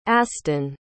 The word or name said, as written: aston